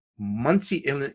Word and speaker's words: Muncie: So now you are a writer, comic convention panel moderator, a teacher, and the owner of All Yak Comics in Muncie, Indiana. Did I pronounce that correctly? – You did. Very good.